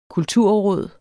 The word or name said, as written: kulturråd